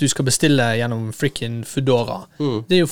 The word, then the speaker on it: Foodora: Jeg sier Foodora. – Foodora. For det er jo en fedora, bare til food. – Ja. – Foodora.